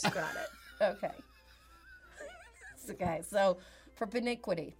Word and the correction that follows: propinquity: propiniquity. – Propinquity. – Oh, well, that P is way too bougie. How do you say it again? – Propinquity.